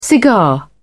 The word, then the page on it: cigar: British English